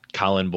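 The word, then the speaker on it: Collin: Definitely the best place would be on Twitter, at Colin Borns.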